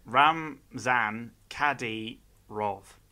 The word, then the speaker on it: Ramzan Kadyrov: Ramzan Gadyarov. I assume that's Russian? – Hang on, Ramzan Gadyarov. – How would you spell it? – Ramzan Kadyrov.